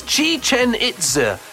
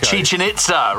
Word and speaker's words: Chichen Itza: No, it's Chichen Itza. In Mexico. – Chichen Itza, right, okay. Nice pronunciation.